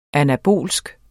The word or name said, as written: anabol